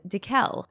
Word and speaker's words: Dekel: My parents spelled my name wrong. They spelled it D-A instead of D-E. Ooh. So I would tell people in America that my name was Dekel.